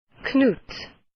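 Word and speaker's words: Knut: This is the official pronunciation according to Scholastic. – Canutes. – Canutes. Let's hear it again. – Canutes. – Canutes. – Those British people. – That's ridiculous. – Canutes. – Well, no, they have the little pronunciation spelling and it's got an apostrophe after the K, so... Canutes.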